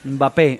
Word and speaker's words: Mbappé: Mbappé, Mbappé.